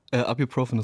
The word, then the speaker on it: Ibuprofen: Wie spricht man es richtig aus? – Ibuprofen ist richtig.